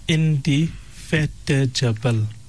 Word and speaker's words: indefatigable: It's pronounced as indefatigable. Indefatigable. It's spelled I-N-D-E-F-A-T-I-G-A-B-L-E.